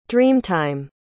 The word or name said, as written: dreamtime